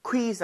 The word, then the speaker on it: quiz: 아, 그나저나 읽을 때 이거 퀴즈라고 하지 마시고요. 우이를 따로 다 발음해야 돼요. – Quiz. – 퀴즈. 그 대신에 아. – 빠르게 하는 것뿐이에요. So, quiz 아니라 quiz, quiz.